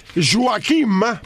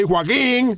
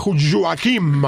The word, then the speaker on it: Joaquim: Joaquim, porque es con M, ¿ok? Así que no se equivoquen, no digan por ahí Joaquín, no, es Joaquim, ¿ok? Porque hay que pronunciar estos nombres catalanes bien.